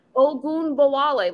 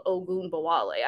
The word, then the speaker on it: Ogunbowale: Like A-R-I-K-E is Arike, not Arike, not R-E-K. It's Arike. – Yeah. – Ogunbowale. Like, it's literally spelled Ogunbowale.